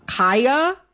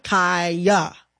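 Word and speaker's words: Khia: I am going to, in this conversation, pronounce Kaya, the rapper, with the long I. Kaya, okay?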